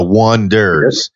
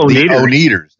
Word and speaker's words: Oneders: And they had a band called The Wonders, and they wanted to spell it O-N-E-D-E-R-S, The Wonders. And it looked like O-Neaters. – That's right.